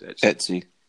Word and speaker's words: Etsy: Have I said that right? Etsy? ST? Whichever way that is. Etsy. Yeah.